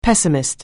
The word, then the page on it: pessimist: anglais